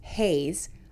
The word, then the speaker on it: HAES: Health at every size and HAES, pronounced Haze, are registered trademarks of the Association for Size Diversity and Health and used with permission.